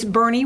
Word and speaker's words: Boerne: it's Bernie Radio, and it's spelled B-O-E-R-N-E, which is not your normal way to... spell Bernie. So I always tell people it's B-O-E-R-N-E.